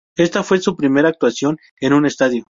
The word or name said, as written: estadio